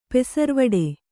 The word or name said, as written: ಪೆಸರ್ವಡೆ